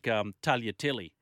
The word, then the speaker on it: Tagliatelle: Annoys me as much as sounding the G in Italian, where it's meant to be silent. Yeah, like Tagliatelle, for example.